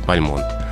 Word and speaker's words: Бальмонт: Прошу читателя, согласно носителю, произносить с ударением на конце «Бальмонт». Поэтому, несмотря на то, что в некоторых словарях все-таки можно встретить «Бальмонт», правильнее говорить «Бальмонт», Константин Бальмонт.